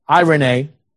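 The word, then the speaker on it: eirene: It's the Greek word E-I-R, long E, N, long E, irene, from the root iro, meaning to join.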